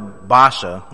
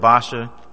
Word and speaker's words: Baasha: We see Asa's trouble with Baasha, the king of Israel. Remember, we're in Judah right now. Or Baasha. I heard someone pronounce it that way. Baasha wants to seal Judah up, and that's an act of war.